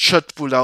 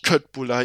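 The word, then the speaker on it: köttbullar: Und ja, es heißt Schöttbula und nicht Köttbula, ihr ganzen Aussprachewichser.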